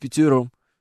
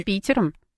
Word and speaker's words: Питером: А где изменения-то ударения? – В пятером. – А в, как надо? В под Питером. Правильно, конечно же.